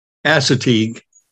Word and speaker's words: Assateague: We've dug out these places, and So the Assateague, is that how you say it? How do you say it? Assateague? – Assateague.